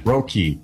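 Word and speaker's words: Roki: How the hell do you say that? Sasaki. – Not like that. I'll tell you, you don't say it like that. – Roky. Yes, Roky. Anyways, Miller started the season on the IL.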